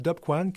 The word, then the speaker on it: Topqan: Je sais que c'est écrit T-O-P-Q-A-N, mais c'est Dop Kwan que ça se prononce en langue innu.